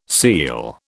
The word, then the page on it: seel: British English